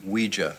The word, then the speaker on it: Ouija: It's pronounced Ouija. – I'm sorry, the Ouija board. He's very obnoxious about the pronunciation. – That's not Ouija.